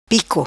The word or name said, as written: Pico